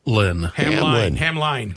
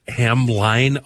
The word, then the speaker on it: Hamline: Hamline. – If I just write Hamline, I'll pronounce it every single time. Hamline. It's Hamlin.